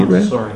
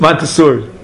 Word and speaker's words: Montessori: What's it called? Mansuri? I'm not sure if I'm pronouncing Montessori. – It right. Montessori.